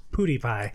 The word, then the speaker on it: PewDiePie: I had no idea how to pronounce PewDiePie.